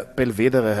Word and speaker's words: Belvedere: Das prominenteste Beispiel in Wien ist das Schloss Belvedere, das tatsächlich italienisch ist und daher Belvedere heißen müsste. Also das E ist nicht stumm am Ende. Es wird aber tatsächlich immer Belvedere ausgesprochen, was eher französisch wäre.